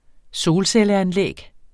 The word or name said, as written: solcelleanlæg